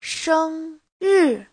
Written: sheng1ri4

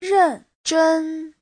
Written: ren4zhen1

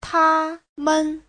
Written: ta1men5

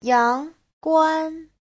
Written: yang2guan1

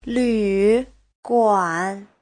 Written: lv3guan3

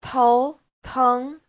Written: tou2teng2

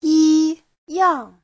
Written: yi1yang4

